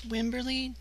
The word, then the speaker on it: Wimberly: N-I-K-O-L-E. Thank you. Would you mind if I ask for your last name? – It's Wimberly, W-I-M-B-E-R-L-Y. – W-I-M-B as in boy, E-R-L-Y.